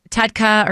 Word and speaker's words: TUDCA: So Tudka or Tudka, I've heard it pronounced both ways. – Can you spell it then? – Yep. So it's capital T-U-D-C-A.